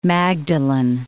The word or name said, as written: Magdalen Islands